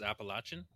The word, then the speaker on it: Appalachian: Is it Appalachian or you said it? – Appalachian. – Way. Appalachian. – Oh, okay. – That's how it's proper pronounced, Appalachian. – It's Appalachia, Appalachian, you know, but if you're from here, it's Appalachia, the Appalachian Mountains, you know, that's the way we say it.